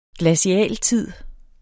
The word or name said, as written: GLACIALTIDEN